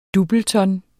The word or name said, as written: doubleton